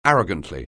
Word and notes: arrogantly: anglais

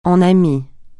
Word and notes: en ami: francais

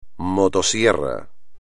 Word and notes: motosierra: espagnol

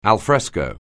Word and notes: alfresco: English

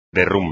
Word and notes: derrumbe: espagnol

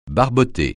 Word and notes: barboter: francais

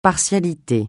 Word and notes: partialité: francais

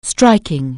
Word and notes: striking: anglais

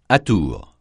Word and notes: atours: francais

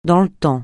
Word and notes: dans le temps: francais

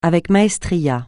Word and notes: avec maestria: francais

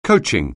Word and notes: coaching: anglais

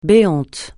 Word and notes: béant: francais

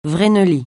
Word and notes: vreneli: francais

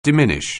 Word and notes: diminish: anglais

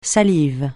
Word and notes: salive: francais

